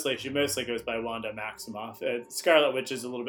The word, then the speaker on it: Maximoff: Was it Maximoff? Is that how you pronounce her last name? – Mm-hmm. Yep. Yeah.